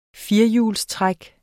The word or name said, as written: firehjulstræk